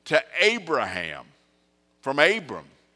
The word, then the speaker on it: Abraham: And in the Hebrew, the difference in those two words are just one little letter that looks like an apostrophe. And you pronounce it with just a breath. Instead of Abram, it was Abraham.